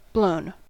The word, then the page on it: blown: American English